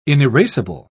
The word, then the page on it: inerasable: British English